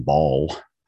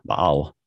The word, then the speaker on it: Baal: But then they also throw in Baal or Baal, depending on how you want to pronounce it,